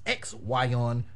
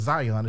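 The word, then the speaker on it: Xyon: Santos Escobar versus X-Yon Quinn. Yeah, I fully pronounced that shit because Zion is just boring.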